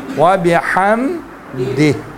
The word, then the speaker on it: وبحمدہ: ہاں دہ پڑھیں گے تو یہ اردو تلفظ ہو جائیں گے، عربی تلفظ کیا ہے؟ دال زیر دی ہے، دال حاضر دی ہو جائیں گے، وبحمدہ ہو جائیں گے، ٹھیک ہے.